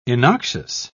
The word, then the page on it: innoxious: British English